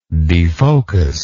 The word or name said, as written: defocus